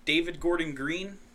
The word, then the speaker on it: David Gordon Green: It's being directed by David Gordon Green.